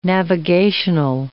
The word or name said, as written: navigational